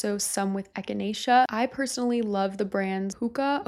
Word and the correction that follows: Pukka: Okay, I thought it was called Puka, but I think it's pronounced Pukka. It's P-U-K-K-A.